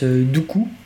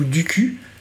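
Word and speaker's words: Duqu: Oui, dans la même lignée, après, on a aussi le virus Dooku ou Duku, ça dépend d'où on vient et comment on le prononce.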